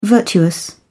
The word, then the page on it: virtuous: British English